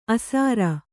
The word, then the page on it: ಆಸರ: Kannada